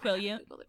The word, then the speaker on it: Quileute: Quileute. – What? – Quileute? At least that is how it is pronounced in the movies. Could be wrong.